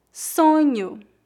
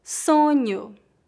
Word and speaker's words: sonho: Observe que a vogal O é pronunciada como U no final das palavras. Não dizemos sonho, e sim sonho.